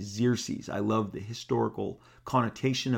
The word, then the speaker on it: Xerxes: So Xerxes, great name, got the X's in there, sounds like Z's.